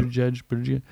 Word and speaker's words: Buttigieg: You know, interesting fact, Buttigieg, am I saying that right? – Yeah. – Buttigieg.